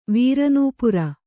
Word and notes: ವೀರನೂಪುರ: vīra nūpura

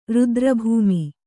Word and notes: ರುದ್ರಭೂಮಿ: Kannada

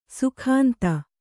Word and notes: ಸುಗಂಧ: sugandha

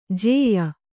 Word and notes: ಜೇಯ: jēya

